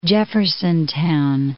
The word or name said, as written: Jeffersontown